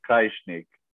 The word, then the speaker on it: Krajisnik: The, the J is pronounced as a Y, so it's Krajisnik.